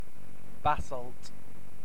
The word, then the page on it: basalt: British English